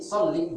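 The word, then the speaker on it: صلِّ: Donc on dit salli, doublement du lèvre sans prolongation.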